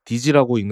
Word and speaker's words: DSEE: 아무튼 아까 얘기하던 아폴로 컴퓨터는 이 SEE의 개념을 가져와서 아폴로 컴퓨터 워크스테이션에 소프트웨어 공학 시장의 점유율을 확대하고 소프트웨어 개발 프로젝트를 지원하기 위해서 DSEE, DG라고 읽는다고 그랬죠. 즉 도메인 소프트웨어 엔지니어링 엔바이러먼트를 개발하게 된 것입니다.